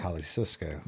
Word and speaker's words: Jalisco: Jalisco. – There you go. Jalisco. – I only knew that because they get this fire sauce in this little small place in Austin. – And it's called Jalisco.